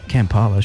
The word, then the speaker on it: Kampala: That's how you say it. Kampala, surely. – I think so. Yeah. – Yeah, that's it.